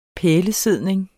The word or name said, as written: pælesidning